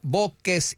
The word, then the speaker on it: voces: Donde el Diablo dio las tres voces y nadie le oyó. – I honestly didn't understand you guys. – What was V-O-C-E-S?